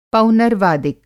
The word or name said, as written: पौनर्वादिक